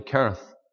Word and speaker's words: Cherith: Mr. Douglas taught us to say Cherith. The CHs in the Bible, you pronounce them as a K. You don't say the chemist shop, you say the chemist. There's a harder sound, the brook Cherith.